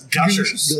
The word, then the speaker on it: Gushers: It's jam-packed with Gooshers. And God damn it, dude. Would you just fucking say it right? – Gooshers. – It's Gushers.